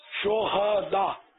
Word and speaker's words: شہداء: یہ شہداء لفظ نہیں ہے، شہداء ہاں پر زبر ہے،